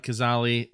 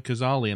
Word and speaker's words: Cazale: The cast, Gene Hackman, John Cazale, which is how I was told to pronounce it. Mm-hmm. My whole life, I called him John Cazale. – I'm sure I watch people talking about him, and they're like, yeah, John Cazale. And I'm like, that's how you say his name?